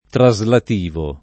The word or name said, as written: traslativo